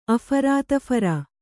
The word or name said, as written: ಅಫರಾತಫರು